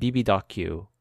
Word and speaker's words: bb.q: Also, is BBQ, is that how you're supposed to pronounce it, or is it bb.q? – No, it's BBQ. – Okay. – Well, I mean, Koreans just say BBQ.